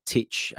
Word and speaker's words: Tite: And I'm actually shocked that Tite has decided, or Titch, as I hear it's pronounced, has decided to overlook Gabriel for him.